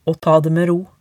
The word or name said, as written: å ta det med ro